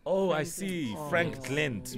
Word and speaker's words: Frankglent: First of all, how do I pronounce the name of the business? – Okay, it's a Frankglent. – Oh, Frankglent.